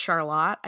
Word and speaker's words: Charlotte: And also in Vermont, Charlotte is always pronounced Charlotte.